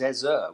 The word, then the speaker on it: Eze: And it's Eze, which I didn't realise. I thought it was Eze, and it sort of rose on the... on the final syllable.